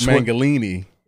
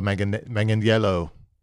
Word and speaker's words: Manganiello: As Joe Arnold Mangalini. – Yeah, as Hulk. I think it's Arnold Schwarzenegger said, Joe Manganiello.